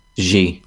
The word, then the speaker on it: Xi: Xi. – Xi. Okay. – X in Chinese is pronounced kind of like a sh.